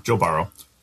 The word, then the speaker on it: Burrow: But I don't hear a problem with the way you say Burrow. – Thank you. Joe Burrow.